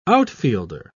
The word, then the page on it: outfielder: British English